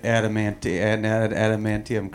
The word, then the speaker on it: adamantium: By the way, Doug said adamantium correctly. That was the way to say it.